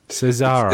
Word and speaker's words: Cesare: Cesare. It's from French, but it's translated into German. – Cesare. – So you have the harder Cesare, not Cesare.